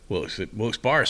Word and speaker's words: Wilkes-Barre: Wilkes-Barre, spelled bar, but they say I heard it's pronounced Wilkes-Barre.